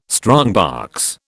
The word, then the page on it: strongbox: British English